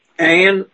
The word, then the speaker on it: Ayn: And apparently, he asked her how she pronounces her name, and she said, Ayn as in, Ayn as in swine.